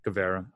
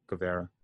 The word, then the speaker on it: Guevara: and they'll use that to continue the thing with Sammy Guerva, right? Guevara. I always say his name wrong. Guevara.